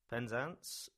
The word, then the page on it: Penzance: English